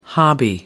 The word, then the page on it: hobby: American English